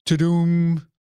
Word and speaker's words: Tudum: Netflix held its Tudum.